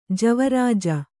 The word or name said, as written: ಜವರಾಜ